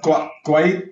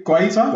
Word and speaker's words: Guaita: Uh, sorry, pronounced his name. No, go. Guaita. Guaita. Guaita. – Okay, yes.